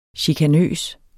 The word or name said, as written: chikanøs